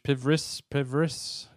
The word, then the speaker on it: PVRIS: How you pronounce this, Rich? Pivris, is it? – Yeah, PVRIS. – Yeah, give me a Pivris, minute.